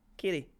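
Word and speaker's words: Keady: If you're from Belfast, it's Katy, right? – Is it? – Yeah, because that's how it's spelled.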